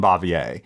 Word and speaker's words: Bavier: Oh, there is no way Aunt B's pronounced her last name Bavier. So it is. I'm telling you.